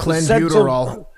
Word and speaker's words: clenbuterol: But clenbuterol.